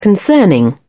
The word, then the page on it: concerning: British English